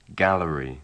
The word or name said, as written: gallery